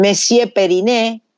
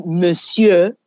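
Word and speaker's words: Monsieur Periné: Messier Periné, acá dice Monsieur, ¿viste? Porque se escribe así, pero digamos Messier, Monsieur, porque tampoco podemos decir Monsieur, porque eso es, es trop, trop français.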